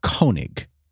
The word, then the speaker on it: Koenig: I'm not in love with Vic Caning. – I believe it's Koenig. – Is that how you pronounce it? – I looked up the pronunciation guide.